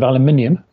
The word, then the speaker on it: aluminium: Forgive me for my pronunciation of aluminium. – No, you did it quite well.